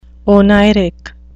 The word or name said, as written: oneiric